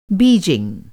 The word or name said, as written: बीजिंग